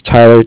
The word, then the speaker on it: Tyler: And my name is Tyler, T-Y-L-E-R, and I'm calling with Cardiff. It's spelled C-A-R-D-I-F-F, as in Frank.